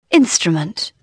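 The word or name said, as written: instrument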